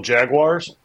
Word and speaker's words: Jaguars: Hey, before we get started, how do you pronounce the team name of the Jacksonville franchise of the National Football League? – What is it? Jacksonville Jaguars? – Thank you very much. Thank you, sir.